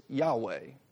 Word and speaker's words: Yahweh: And the way that we would write it is YHWH, but it's Yahweh. Is how we have come to pronounce the divine name.